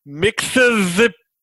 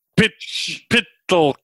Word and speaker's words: Mxyzptlk: Can you kind of give us a little breakdown on who Mxyzptlk is? Hold on. For those at home. – Just give it one good college try. Okay. You can do it. – Mr. Mxyzptlk. Mxyzptlk. – Dot, dot, dot. Close enough. – This is spelled M-X-Y-Z. – Yep. – P-T-L-K.